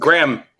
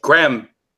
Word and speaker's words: Graham: Only one syllable. It's just Graham. It's just Graham.